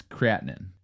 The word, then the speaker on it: creatinine: It's spelled the exact same way, but it's creatinine is how it's pronounced in the medical field. – Creatinine? – Creatin. – Creatine. – C-R-E-A-T-I-N-E.